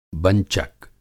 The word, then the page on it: बंचक: Hindi